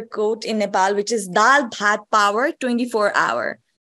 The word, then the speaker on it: dal bhat: Uh, dal bhat.